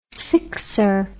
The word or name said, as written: sixer